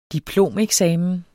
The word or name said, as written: diplomeksamen